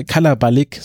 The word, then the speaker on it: Kalabalik: Ähm, das ist nämlich das Wort äh, Kalibalik äh, Kalabalik, so ist es richtig ausgesprochen.